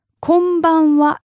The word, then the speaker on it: konbanwa: And finally, we have the evening greeting, which is konbanwa, konbanwa, konbanwa.